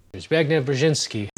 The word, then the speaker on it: Zbigniew Brzezinski: On má strašně složitý jméno, strašně špatně se vyslovuje, ale zhruba takhle nějak to je. – Zbigniew Brzezinski. – Zbigniew Brzezinski.